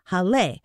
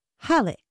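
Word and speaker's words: Hallé: But it is pronounced with the stress on the first syllable, Halle.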